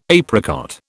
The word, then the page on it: apricot: British English